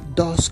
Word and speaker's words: does: Se escribe does pero se pronuncia dos como que si estuvieras diciendo el número dos.